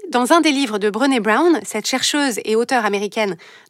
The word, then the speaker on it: Brené Brown: Brené Brown, ça s'écrit B-R-E-N-E et Brown comme la couleur marron.